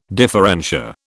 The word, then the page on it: differentia: British English